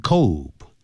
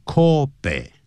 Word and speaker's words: Kobe: It started out in Kobe，不要念成 Kobe，因为这是日文，所以日文的是它就叫念 Kobe，这样念好像呢，就像清酒，日本的这个清酒，英文拼成 S A K E，你要念成 sake，那是英文是代表缘故。